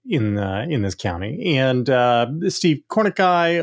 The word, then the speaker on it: Kornacki: But it's pronounced Kornacki. – Oh, okay. – Steve Kornacki, the gay man with the khakis. Khaki Kornacki. It rhymes.